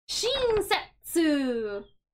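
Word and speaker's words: shinsetsu (親切): And when we put that together, we get shinsetsu.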